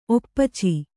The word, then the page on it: ಒಪ್ಪಚ್ಚಿ: Kannada